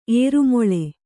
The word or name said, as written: ಏರುಮೊಳೆ